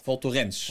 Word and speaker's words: Val Thorens: Ik maakte die fout vroeger ook, maar het is niet de Ans. Dat is het. – Val Thorens. – Val Thorens, ja. Val Thorens. – Dus wel met die uitgesproken S erachter.